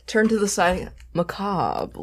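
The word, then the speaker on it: Mechabre: It has been confirmed that it is macabre, but it's spelled like mech and then the back half of the actual word macabre.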